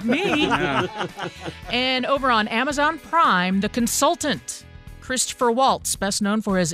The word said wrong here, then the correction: Christoph: And his first name being pronounced Christoph? Yeah, yeah. – Christoph. – Christoph, yeah, yeah. I like did him. I say? Did I add the er on there? – Yeah.